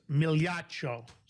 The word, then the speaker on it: Migliaccio: It's Migliaccio. Migliaccio. – Nope. I'm going to correct you. – There we go. – It's Migliaccio. So my full name, if you guys want to hear it, and you can imagine how this has been screwed up my whole life, it's Lance Claudio Migliaccio. That's how it's supposed to be pronounced.